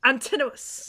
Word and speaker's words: Antinous: The Emperor Hadrian's lover, Antinous. Antinous, yeah. – Antinous, yeah.